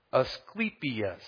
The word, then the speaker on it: Asclepius: Asclepius